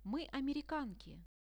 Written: my americanki

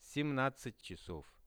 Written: 17-chasov

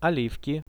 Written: olivki

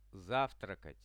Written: zavtrakat

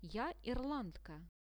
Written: ja irlandka

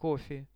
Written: kofe

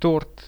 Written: tort

